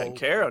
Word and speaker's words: Banchero: Including the top kid, this Paolo Banchero.